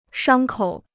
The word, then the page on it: 伤口: shāng kǒu